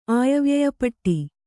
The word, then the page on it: ಆಯವ್ಯಯಪಟ್ಟಿ: kannada